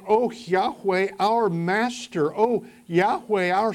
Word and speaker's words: Yahweh: As we've mentioned previously, whenever the word Lord occurs in small caps, it's a reference to the covenant name of God, the special name that God gave Israel, sometimes pronounced Yahweh.